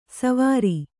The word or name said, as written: ಸೌವೀರ